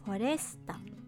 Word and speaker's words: フォレスタ: 数字の 4 0 s STA これでフォレスタ .net もしくはフォレスタ